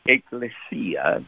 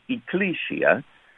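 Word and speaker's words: ekklesia: Actually, I spell it intentionally with double K, and I pronounce it ecclesia, which is closer to the Greek pronunciation, rather than ecclesia, because ecclesiastic has to do with religiosity, okay?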